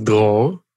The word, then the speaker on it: Dror: So my name is Dror, which means freedom in Hebrew, if you care.